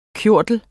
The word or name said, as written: KJORTLERNES